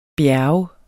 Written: bjerge

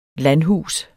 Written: landhus

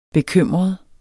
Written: bekymret

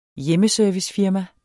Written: hjemmeservicefirma

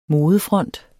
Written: modefront

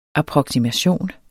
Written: approksimation

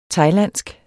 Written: thailandsk

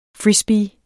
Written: frisbee

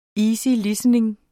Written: easy

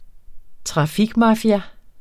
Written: trafikmafia

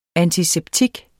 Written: antiseptik

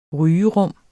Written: rygerum

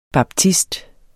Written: baptist

